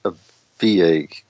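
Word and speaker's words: Avilés: In September, there's in Spain, I believe it's a VIG is how it's pronounced, A-V-I-L-E-S.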